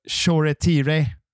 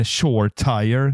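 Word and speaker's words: Shoretire: Och det här uttalet, Choretire, som man ska säga, eller Shore-Tire, som eh, amerikanerna säger.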